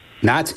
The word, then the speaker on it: knots: Knots. – Knots is correct. There's your silent letter right there. K-N-O-T.